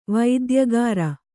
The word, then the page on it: ವೈದ್ಯಕಾರ: vaidyakāra